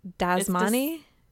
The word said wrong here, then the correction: Dasani: It's Dasani. – Oh, Dasani. Oh.